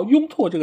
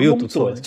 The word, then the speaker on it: 拥趸: 就是下一次在说到“ 拥拓” 这个词的时候，能不能读对它的读音？应该叫“ 拥趸”。